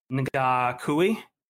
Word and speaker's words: Ngakoue: Number eight on my list is another Jaguars defensive end, Yannick Nega. I can't say his name, man. Yannick Ngakoue? Yeah.